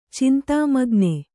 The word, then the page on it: ಚಿಂತಾಮಗ್ನ: kannada